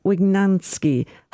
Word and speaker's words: Wygnanski: Our music choices this morning have been chosen by my guest, Father Peter Wygnanski.